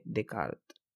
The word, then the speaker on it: Descartes: De hecho, aunque tenga dos S en su apellido, como le decimos normalmente acá, Descartes no se pronuncia ninguna de las dos, es René Descartes, que nace en el año 1596 y muere en el 1650.